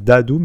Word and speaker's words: Daadhoo: alors, pour le retrouver sur les réseaux, c'est important parce qu'en fait, c'est Dadou, mais ça s'écrit D-A-A-D-H-O-O. Ah oui. Donc c'est important de l'épeler parce que sinon c'est dur à trouver, c'est pas D-A-D-O-U.